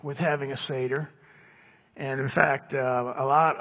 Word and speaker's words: Seder: Obviously, a lot of us have never celebrated a Passover Seder. It's spelled S-E-D-E-R, Seder.